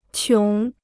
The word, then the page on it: 穷: qióng